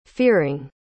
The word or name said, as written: fearing